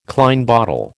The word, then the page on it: Klein bottle: American English